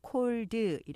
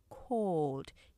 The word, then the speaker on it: cold: 콜드 이렇게 이음절로 하시면 안 되고요. cold 이름절로 하셔야 돼요.